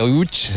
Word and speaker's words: Łódź: Aunque se pronuncia Łódź, se escribe Łódź, por si alguien lo busca Łódź, en Google.